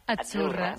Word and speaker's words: Azzurra: Azzurra. Sí, como porque pizza. La Z no es la Z como decimos en catalán tan sonora, no. Ah, es Azzurra. – Muy bonito, muy bonito. – Es como pizza.